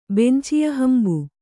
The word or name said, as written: ಬೆಂಚಿಯಹಂಬು